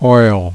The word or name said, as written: oil